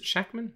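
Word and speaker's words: Shakman: WandaVision director Max Shackman. Is it Shackman? It's how it's spelt.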